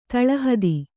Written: ತಳಹತ್ತು

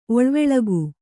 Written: ಓಳಿವೆಳಗು

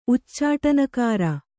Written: ಉಚ್ಚಾಟನಕಾರ

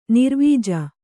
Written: ನಿರ್ವೀಜ

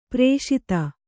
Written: ಪೃಷಿತ

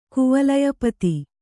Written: ಕುವಲಯಪತಿ

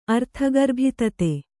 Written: ಅರ್ಥಗರ್ಭಿತತೆ

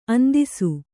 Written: ಅಂದಿಸು

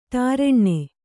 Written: ಟಾರೆಣ್ಣೆ

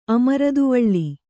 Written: ಅಮರ್ದುವಳ್ಳಿ

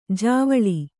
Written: ಜವಳಿ

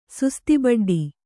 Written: ಸುಸ್ತಿಬಡ್ಡಿ

